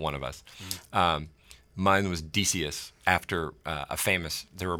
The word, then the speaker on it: Decius: It's actually pronounced Decius, although I always pronounced it Decius.